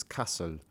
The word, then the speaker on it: Kassel: Cassel. Or Kassel. I checked the pronunciation on that. I think it's Kassel.